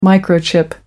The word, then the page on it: microchip: American English